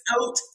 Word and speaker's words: Haute: In 2013, you opened Haught & Company Bridal Boutique. I think I pronounced that right. – No, you did not get it. It is French. It is Haute & Co. Bridal Boutique.